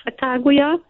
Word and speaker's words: Sacagawea: It's Sacagawea or Sacagawea. It depends on what part of the country you're in.